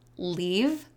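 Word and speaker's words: Leav: But one quote I'm going to read from my favorite poet, and I'm pretty sure her last name's pronounced Leave, but it's Lang Leave.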